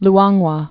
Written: Luangwa

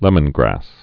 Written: lemongrass

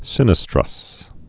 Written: sinistrous

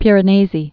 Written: Piranesi

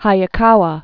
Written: S. I. Hayakawa

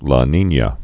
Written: La Nina

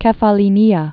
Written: Kefallinia

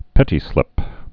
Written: pettislips

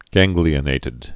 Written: ganglionated

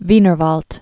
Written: Wienerwald

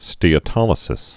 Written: steatolysis

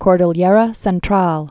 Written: Cordillera Central

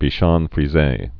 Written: bichons frises

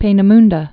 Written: Peenemunde

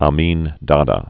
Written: Idi Amin Dada

